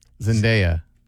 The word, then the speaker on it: Zendaya: It's pronounced Zendaya.